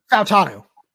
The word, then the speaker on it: Fautanu: How do you pronounce his name? It's Troy Fautanu. How do you say it? – Fautanu.